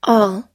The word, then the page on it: all: American English